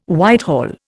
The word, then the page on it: Whitehall: Indian